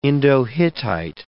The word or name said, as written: Indo-Hittite